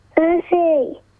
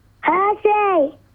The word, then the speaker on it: Percy: Percy. – What's your name? – Percy. – One more time. – Percy. – Tell, how do you spell that? Sounds like you're saying Hershey. – P-E-R-C-Y.